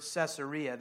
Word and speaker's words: Caesarea: Now, if you have your Bible open and you can see the word Caesarea, that's how it's pronounced, but that's not really how it's phonetically spelled. You can't say the word Caesar without saying Caesarea.